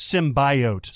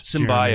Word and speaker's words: symbiote: And then just the general idea of the action where the suit is fighting for him, or the symbiote is, before he even realizes what it can do. – It's symbiote. – Symbiote. – I'm really kidding. – It actually is symbiote.